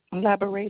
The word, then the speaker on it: Laburay: Labore is spelled L-A-B-U-R-A-Y.